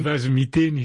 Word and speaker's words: Vazomiteni: corrigez ma prononciation, des Vazomiteni, comment on dit ? – Des Vazomiteni, ça veut dire...